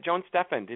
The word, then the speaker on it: Steffend: You know, the Joan Steffend, am I pronouncing her name properly? – You got it, Joan Steffend, indeed.